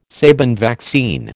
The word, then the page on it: Sabin vaccine: American English